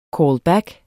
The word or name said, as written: callback